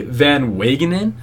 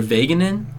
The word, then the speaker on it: Van Wagenen: So it was the GM, and I have to say his name, Brody Van Wagenen, I believe, or Vaganen. It's the sickest name I've ever seen. Wagenen? It could be one of those like W's that's pronounced like a V.